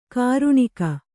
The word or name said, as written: ಕರಣಿಗ